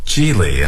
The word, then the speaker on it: Gigli: And, for example, this year I'm sure that Ben Affleck and Jennifer Lopez's movie called Geely will win that prize. – 哦，说和奥斯卡每年的大奖相对应的呢是奥斯卡，不是奥斯卡了，就是有些组织呢每年都要颁发最差电影奖。今年呢就有一个啊、，The name of the film again? – It's called Geely, and it's spelled G-I-G-L-I.